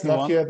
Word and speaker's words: Nokia: I'm not sure how you pronounce it in English, but yes, no, it's Nokia. That's fine. That's perfect.